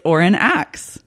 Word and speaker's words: Oren Axe: It's Oren Axe. – Hello, hello. – Did I say that right? – Yeah. – Okay, good. I was worried. I didn't know if it was like ox or axe, but I went with my gut and I think I pulled it off.